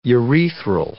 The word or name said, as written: urethral